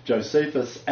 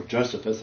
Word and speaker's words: Josephus: So both Josephus and, or Josephus as it should be pronounced,